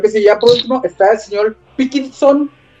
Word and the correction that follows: Pilkington: si ¿sí lo pronuncio bien. Sí, creo que sí. Sí, Pilkington.